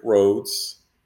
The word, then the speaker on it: Rhodes: So you can actually go to Eventbrite and go to Rick Rhodes. Last name is spelled R-H-O-D-E-S.